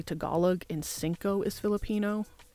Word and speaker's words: singko: It is cinco. Again, it sounds exactly the same as the Spanish word cinco. Spanish, it's spelled C-I-N-C-O. And in Filipino, it is spelled S-I-N-G-K-O.